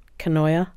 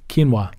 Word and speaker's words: quinoa: Canoia? – Quinoa.